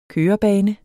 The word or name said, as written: kørebane